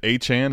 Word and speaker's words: Achane: Achane is officially the pronunciation, right? – Listen, when you go for a 50 burger, I guess you can just call yourself whatever the fuck you want. – Well, I'm actually trying to get it correct. Is it Achane? Is that official? Achane. – Yeah, I guess that's the pronunciation.